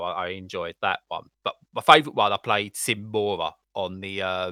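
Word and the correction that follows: Sine Mora: Simmora? Help me pronunciation. – Yeah, I think it's Cinemora. Yeah. – Cinemora, there we go.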